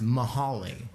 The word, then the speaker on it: Mount Holly: You guys, like, they my friends live in this town called Mount Holly, but it's pronounced Mahali. But then there's Mount, what's the mount next to it? – Laurel. – Mount Laurel's just Mount Laurel. It's not Malaurel.